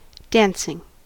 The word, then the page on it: dancing: American English